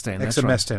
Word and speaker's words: exemestane: And you were saying earlier that the side effects of exemestane, have I said that right? – Exemestane.